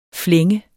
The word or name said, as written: flænge